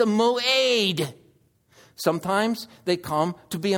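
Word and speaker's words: mo'ed (מועד): And so we have a word in the Old Testament that is pronounced mo'ed, mo'ed.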